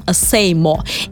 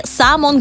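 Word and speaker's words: SAMO: Так вот, рисовал он, э, а точнее писал надписи э, на улицах под ником Сеймо. Или сам он говорит и поправляет там в одном из интервью журналиста, он говорит «само». То есть он на «о» ставит ударение, так по-французски.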